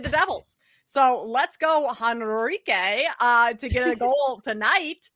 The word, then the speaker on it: Henrique: Did I completely butcher it? It's Henrique. – It's Adam Henrique.